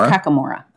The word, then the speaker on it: Kakamora: Kokomora, am I saying that right? – Kakamora. – I think it's Kakamora.